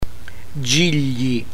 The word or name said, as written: gigli